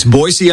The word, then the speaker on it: Boise: People always say it with a Z, right? – That's right. It's Boise, Idaho.